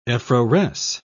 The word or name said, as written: effloresce